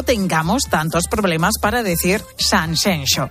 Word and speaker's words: Sanxenxo: Cuando vamos a dar alguna ponencia, sobre todo fuera, es decir, en el resto de España, en las ponencias al final siempre ponemos el tema Sanxenxo como Shakira.